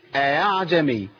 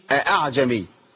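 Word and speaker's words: ءأعجمي: كلمه اعجمي بيتعامل معاها بتسهيل الهمزه، يقول ايه اعجمي ما اقولش اعجمي.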